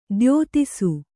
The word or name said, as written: ದ್ಯೋತಿಸು